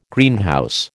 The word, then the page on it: Greenhouse: American English